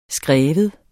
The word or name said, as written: skrive